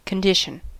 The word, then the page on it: condition: American English